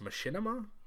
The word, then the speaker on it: Machinima: That's how we got here to a company that made, I believe it's pronounced Machinima? – Yes. – Correct me if I'm wrong.